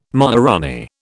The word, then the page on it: maharanee: British English